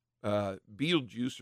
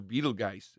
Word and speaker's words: Betelgeuse: But there's a reddish star in the upper, I think it's kind of in the upper shoulder, probably left shoulder of Orion, called Betelgeuse or Betelgeuse. It's pronounced both ways.